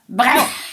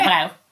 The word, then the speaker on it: Breuer: Ja, det är Marcel Breuer, hur uttalar man det? – Breuer. – Jag säger det snabbt, Breuer.